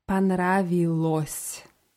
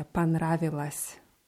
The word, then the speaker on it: понравилось: Und nach Vokalen wird aus ся si, also s Weichheitszeichen, s мягкий Panravilos. – Panravilos. – Ja. Dieses O wird aber eher wie A ausgesprochen. Ja, Panravilas.